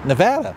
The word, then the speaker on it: Nevada: Nevada. – Are you sure? – Not. – Right. It's the same vowel sound as craps and blackjack. Just think of that.